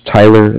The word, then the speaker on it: Tyler: Again, my name is Tyler. That's T-Y-L-E-R. And I'm calling with Cardiff. It's spelled C-A-R-D-I-F-F, as in Frank.